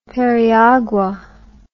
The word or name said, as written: periagua